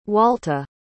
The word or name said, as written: walta